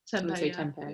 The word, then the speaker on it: tempeh: Tempeh.